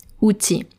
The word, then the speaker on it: outil: The challenge is that when you're reading it, there's a final L. Outil. O-U-T-I-L. And you might be tempted to pronounce that L. But no, you should not do that. It's un outil. Pretend the L is not there.